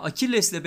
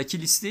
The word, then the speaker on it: Akilles: Yine Akilles'in bu arada böyle okunuyor. yani Aşil ya da Akilis değil Akilles diye okunduğunu öğrenmiştim bir arkadaşımdan.